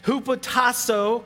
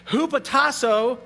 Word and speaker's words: hupotasso: I know I pronounced this really badly. Hupotasso. Hey, I did it. Hupotasso, this Greek word, is best defined in Philippians 2, verse 3, where it says this.